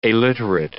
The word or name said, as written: aliterate